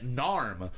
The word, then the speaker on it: Naarm: But I believe it is Narm. Narm is the indigenous name for the area that Melbourne is in. N A A R M, and they bill themselves as being from Narm/Melbourne.